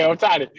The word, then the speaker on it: Ohtani: Okay, Ohtani.